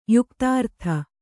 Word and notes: ಯುಕ್ತಾರ್ಥ: yuktārtha

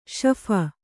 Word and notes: ಶೇಫ: śepha

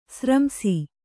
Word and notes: ಸಾರಾಂಶ: sārāma'

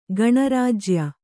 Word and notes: ಗಣರಾಜ್ಯ: gaṇa rājya

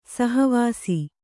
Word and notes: ಸಹವಾಸಿ: saha vāsi

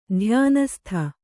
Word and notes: ಧ್ಯಾನಸ್ಥೆ: kannada

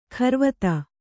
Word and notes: ಕರವತಿ: karavati

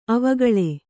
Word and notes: ಅವಿಕಲ: kannada

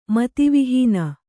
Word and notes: ಮತಿವಿಹೀನ: mati vihīna

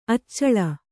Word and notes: ಅಚಲೆ: Kannada

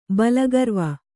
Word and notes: ಬಲಗರ್ವ: Kannada

